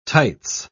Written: tights

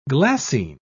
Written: glassine

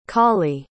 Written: Colie

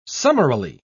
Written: summarily

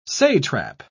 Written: satrap

